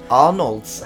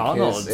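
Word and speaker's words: Arnalds: And Arnolds, I think, Arnolds. Is how we pronounced it, the first one.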